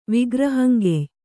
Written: ವಿಗ್ರಹಂಗೆಯ್

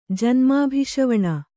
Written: ಜನ್ಮಾಭಿಷವಣ

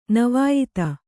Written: ನವಾಯಿತ

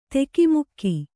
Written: ಧಕ್ಕೆಮುಕ್ಕೆ

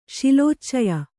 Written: ಶಿಲೋಚ್ಚಯ

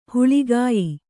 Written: ಹಾಳ್ಗೆಯ್

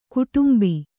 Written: ಗೋಡುಂಬ

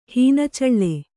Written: ಹೀನಚಳ್ಳೆ

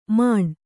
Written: ಮಾಣ್

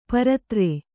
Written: ಪರಿದರ

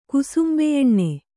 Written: ಕುಸುಂಬೆಯೆಣ್ಣೆ

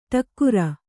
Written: ಢಕಾರ

